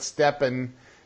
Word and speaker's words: Stepan: Stepan.